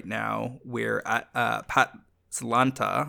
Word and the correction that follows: Pantlaza: have I got that right? Let me just double check the pronunciation. Pantlaza.